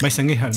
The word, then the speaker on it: Weißenkirchen: Concretamente en Weißenkirchen. – Weißenkirchen. – Sí, hombre. – Ok, sí, sí. – Lo pronunciado excelente. – No mola tanto como Soweto. – Weißenkirchen. – No tengo ni idea, es Weißenkirchen. – Es justo así.